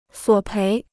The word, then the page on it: 索赔: suǒ péi